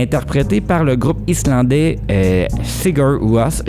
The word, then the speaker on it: Sigur Rós: Je ne sais pas si je le dis bien. – Oui, Sigur Rós. – Oui, tu le dis bien. Ouais. – Ou Sigur Rós. – Ça dépend des gens, mais c'est pas mal ça.